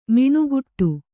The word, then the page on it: ಮಿಣುಗುಟ್ಟು: miṇuguṭṭu